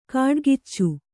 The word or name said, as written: ಕಾಡ್ಗಿಚ್ಚು